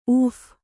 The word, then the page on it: ಉಫ್: uph